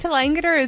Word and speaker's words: Tallangatta: People struggle with Tallangatta as well, because you've got to put the emphasis on Tallangatta. So, we know how to say it, but people say Tallangatta.